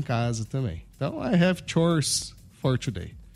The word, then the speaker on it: chores: Chores, né? – É, chores. – Aí a pronúncia é chores.